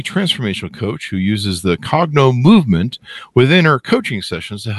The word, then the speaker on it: Cognomovement: How do I pronounce this correctly, Katie? A Cognomovement? – You got it. Yep. Perfect.